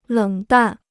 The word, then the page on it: 冷淡: lěng dàn